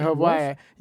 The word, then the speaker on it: Hawaii: I went to Hawaii, yeah, the actual like Polynesian pronunciation is Hawaii. With a V.